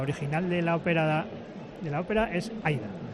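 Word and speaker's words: Aída: El otro día me enteré yo que no se dice Aida, se dice Aida. – Aida, con la A, no.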